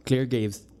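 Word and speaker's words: Clare Graves: Clay, Claire Graves, no se pronuncia así, tú que eres el, experto. – Así es, Claire Graves.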